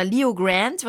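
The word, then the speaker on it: Leo Grande: Und zwar Good Luck to You, Leo Grant, oder Leo Grant wird das ja ausgesprochen, mit Emma Thompson in der Hauptrolle.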